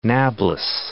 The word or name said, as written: Nablus